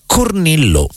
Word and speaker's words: Cornilleau: Era Cornillo, perché penso io con la pronuncia francese così, è scritto Cornilleau,